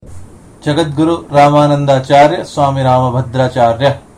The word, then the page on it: Jagadguru Rambhadracharya: Sanskrit